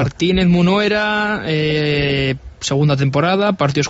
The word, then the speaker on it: Munuera: Martínez Minera. – Munuera, perdón que se me... – Da igual. – Munuera.